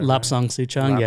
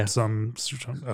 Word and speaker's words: Lapsang Souchong: There are some types of green tea or Lapso Souchong. Did I get that? – Lapsang Souchong, yeah. – Lapsang Souchong, okay.